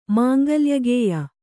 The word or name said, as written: ಮಾಂಗಲ್ಯಗೇಯ